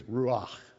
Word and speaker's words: ruach: In the Old Testament Hebrew, the word to breathe is ruach. Ruach. And it's the same word for spirit. Isn't that cool? The breath, spirit, to breathe is ruach.